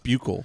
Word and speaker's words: buccal: Buckle cat. Or is it buccal? – I think it's pronounced buccal.